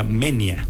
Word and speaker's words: Moenia: Dijimos, Moenia. Ya luego después nos enteramos que se pronuncia Menia, porque es diptongo, entonces va junto.